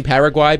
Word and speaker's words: Paraguay: Also, have we been mispronouncing Paraguay? Because someone on our text line brought that up. Am I saying it incorrectly? – It just depends on how strict you want to be with the Spanish pronunciation. Paraguay.